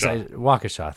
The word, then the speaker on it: Waukesha: Waukesha, thank you. Yeah. And you knew that before this, obviously. If you look at it, it just says Waukesha.